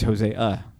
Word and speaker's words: Hosea: No, because it's Hosea. The A needs its pronunciation.